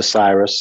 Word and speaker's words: Syrus: So everything is pretty much my name, which is at Christopher Cyrus. Cyrus is spelled S-Y. R-U-S.